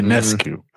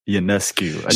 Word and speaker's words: Ionescu: Ionescu. – Wait, say it again. – Ionescu.